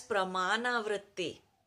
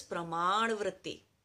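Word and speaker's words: praman vritti (प्रमाण वृत्ति): Now, praman, ra word is, ra sound is not there in English. So in English, we write it as pramana vritti. Pramana vritti. But the real pronunciation of it in Sanskrit is praman vritti.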